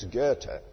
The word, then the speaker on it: Goethe: Now, you have to be able to pronounce that correctly in order to get into heaven. You can't say Goethe or something like that. It's Goethe.